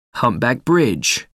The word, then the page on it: humpback bridge: American English